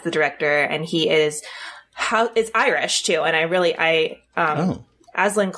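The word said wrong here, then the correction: Aislinn: Clark. Ashlyn Clark, I'm so sorry. I hope I got her name right.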